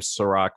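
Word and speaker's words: Serak: Just type in the weekly review, or it might just be easier to type my name, Serac, which is S-E-R-A-K. I know, unfortunately, not spelled the same as the liquor, but I promise you it tastes way better.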